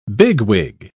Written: bigwig

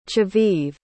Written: chaviv

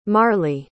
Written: marley

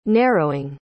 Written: narrowing

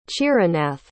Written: chiranath

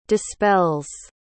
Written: dispels

